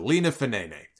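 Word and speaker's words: Lina Fanene: That's how you pronounce it. Lena Fenene.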